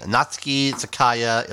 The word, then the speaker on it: Natsuki Takaya: We have here an art book of Fruits Basket, complete anime Natsuki Takaya illustrations.